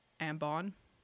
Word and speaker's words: Ambon: The story that I'm going to tell you takes place on an island in Indonesia called Ambon. I've seen it pronounced Ambon, Ambon, Ambon, but. Abon. Abon. A M B O N. I'm going to say Ambon.